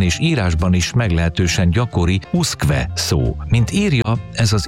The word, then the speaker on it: usque: mégpedig nem eredeti latin alakjában, hanem kiejtés szerint, tehát uszkve, úgy, ahogy ejtjük.